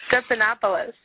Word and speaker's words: Stephanopoulos: Stephanopoulos.